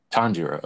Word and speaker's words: Tanjiro: Tanjiro.